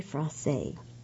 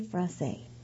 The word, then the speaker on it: frosé: Strawberry frosé, and it's F-R-O-S-E with an accent as it's spelled. And this is Cheers to Summer. Cool off with this frosty cocktail, strawberry frosé.